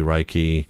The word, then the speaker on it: Reiki: Metaphysically Speaking talks about topics like astrology, Reiki, it's pronounced, and this week it's a psychic medium this coming Tuesday who is blind.